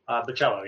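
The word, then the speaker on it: Bachalo: How do you pronounce his name? Where's Eric when you need him? – Somni? – No. – Oh, Bacello? – Is it Bacello? – I think it's Bacello. – Bacello, yeah.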